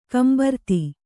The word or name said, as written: ಗುಂಬಾರತಿ